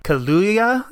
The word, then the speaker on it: Kaluuya: It's Lakeith Stanfield, Daniel Kaluuya. I always say his name wrong. Kaluuya. There's two U's in a row, so I always mess that up.